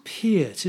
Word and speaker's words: Peart: I believe it's Peart, isn't it? – Is it Peart? – It's like one of those things that he said he would pronounce it Peart.